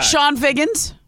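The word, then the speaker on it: Chone Figgins: Sean Figgins. We should have remembered Sean Figgins. – Sure. – Spelled with a CH.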